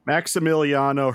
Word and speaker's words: Maximiliano: I got to talk to Maximiliano. I'm saying I'm saying it wrong. Maxi. I just say Max. Maximiliano Hernandez. – Thank you